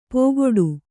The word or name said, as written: ಪೋಗೊಡು